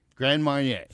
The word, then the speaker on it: Grand Marnier: First off, say Grand Marnier. Grand Marnier. – Grand Marnier. – Thank you. At least say it right.